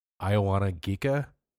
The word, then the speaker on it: Ioanna Gika: Her name is Ioana Gika.